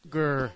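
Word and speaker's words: Zyuohger: Did you say Zuhoger? There's no Hoger? – Zuhoger. – That's not a ho-h.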